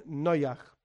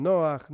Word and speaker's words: Noach: Yo, por mi pronunciación, suelo decir más bien Noach, pero es lo mismo, Noach, Noach, tiene que ver con la pronunciación del hebreo del mundo ashkenazi, del mundo sefaradí.